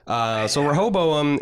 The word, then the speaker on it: Rehoboam: This new way of pronouncing, I've always grown up pronouncing this guy Rehoboam. – Fuck Nolan and Joy for making this the name of the thing, because nobody knows how to pronounce it. It's super annoying to say. It's like, we've got a character called Mircello Hasbaz. What? – Well, yeah, I learned with Mahershala how I actually pronounce another Bible name last year on HBO. So this year I'm getting the retool Rehoboam.